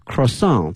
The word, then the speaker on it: croissant: It's a chocolate croissant with rich chocolate inside and outside，后面它是一个巧克力哈，chocolate croissant